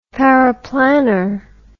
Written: paraplanner

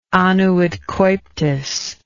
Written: annuit coeptis

